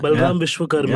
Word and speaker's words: Balram Vishwakarma: It's Balram Vishwakarma.